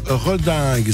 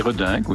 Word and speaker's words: Reding: Serge Redingue, c'est comme ça que vous prononcez, moi j'hésite. – Oui, tout à fait, Redingue, oui. – Moi j'ai l'impression qu'on disait Reding, non? C'est pas ça? – Non, c'est un Ardennais d'origine, donc Serge Redingue.